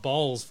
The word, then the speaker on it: Bowles: Hey, I just said bowels and not bowls for Peter Bowles earlier.